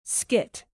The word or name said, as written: skit